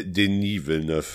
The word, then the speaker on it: Denis Villeneuve: De Denis Villeneuve. Schon. – Der Mann ist Franco-Kanadier. – Ah ja, wenigstens Villeneuve richtig ausgesprochen.